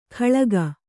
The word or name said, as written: ಖಳಗ